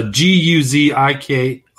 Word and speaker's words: Guzikowski: Okay. Gutkowski. Yeah. Let's see, how's it spelled? Okay. It's a Guzikowski. Sorry.